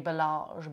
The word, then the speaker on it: Balázs: And I taught for a few, I guess I'd been teaching him for a few years, the famous hotelier André Balázs. I'm trying to say it in the Hungarian way.